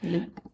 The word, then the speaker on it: Liu: And then I was glad to see 60 down Simu Blank, star of Shang-Chi and The Legend of the Ten Rings, Liu, however mm-hmm. that's pronounced. – Liu. – Liu, okay.